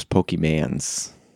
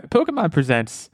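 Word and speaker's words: Pokémon: It was Pokemans. oh gosh, that's even yeah, that's another level beyond what my dad did. But yeah, uh, Pokemon Presents, to pronounce it correctly, uh, live stream event that announced a lot of new uh, new content.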